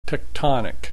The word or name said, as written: tectonic